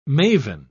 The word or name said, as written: maven